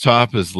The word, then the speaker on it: tapas: You know, the thing about Spain is those tapas. Am I saying it right? The tapas? – Tapas. – Yeah. Tapas.